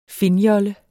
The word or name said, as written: finnjolle